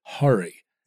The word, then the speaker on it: Hari: Hari Kondabolu, and he said to me many times that the pronunciation of his first name is a struggle for him because white people call him Hari. – Yeah. – And the actual pronunciation, he sat and said, Hari. – It's a schwa.